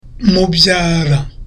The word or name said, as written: mubyara